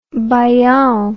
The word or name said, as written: baiao